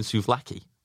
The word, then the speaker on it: souvlaki: I think I'm saying souvlaki. Which it is souv, S-O-U-V-L-A-K-I. Souvlaki. – Laggy.